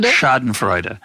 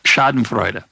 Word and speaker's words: Schadenfreude: Is that the correct pronunciation, by the way? – Yeah, it's pretty good. – How would you say it? – Schadenfreude. – It's a Schadenfreude. I'm going to do a better. You know, as an American, it's very pretentious to pronounce it more accurately. So I'm going to try that.